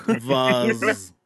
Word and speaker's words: Voss: It's actually pronounced. It's pronounced. Did you just do that? It's pronounced Voss.